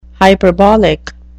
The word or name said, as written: hyperbolic